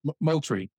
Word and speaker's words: Moultrie: Am I pronouncing that right? – Moultrie. – With an L.